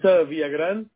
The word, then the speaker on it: Villagran: It's Villagran. It's spelled V as in Victor. – Okay. – I-L-L. – Okay. – A-G-R-A-N as in Nancy.